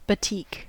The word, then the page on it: batik: American English